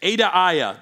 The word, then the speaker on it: Adaiah: Adaiah, that's how you say it, Adaiah.